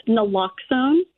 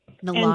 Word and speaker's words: naloxone: Now, what is it pronounced naloxone? – It's pronounced naloxone. – Naloxone.